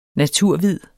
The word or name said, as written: naturhvid